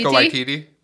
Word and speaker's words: Taika Waititi: If you're a fan of, God, I always butcher his name, Taika Waititi. – Taika Waititi. – Okay, yeah. Good. I got it.